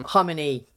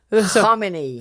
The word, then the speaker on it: Khamenei: You're going to have to help me with the pronunciation. It looks so much like Khomeini. Khamenei. Let me get his name. – Khamenei.